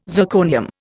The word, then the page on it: zirconium: Indian